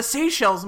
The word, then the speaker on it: Seychelles: That place, what was it, in the Cayman Islands or the Seychelles? Is that how you pronounce that? – Yes. – Seychelles? – Yes.